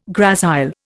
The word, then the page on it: gracile: Indian